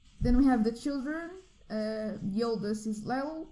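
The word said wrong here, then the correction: Liesl: Lisla? Lyle? How do you say her name? – Show me. Which one? – The first one. – Lisel. – There you go, Lisel.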